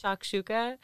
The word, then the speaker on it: shakshuka: How do you pronounce that? Shushka? – Shakshuka? I believe, I think. – Shakshuka,